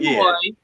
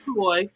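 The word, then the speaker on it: Tuohy: And then in 2004, he was attending a different school in which Ann and Sean Tuhoy. – I didn't know how to pronounce that, but Tuhoy, say Tuhoy.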